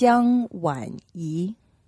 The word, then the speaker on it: Jiang Wanyi: Yes, it's Jiang Wanyi.